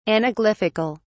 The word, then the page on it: anaglyphical: British English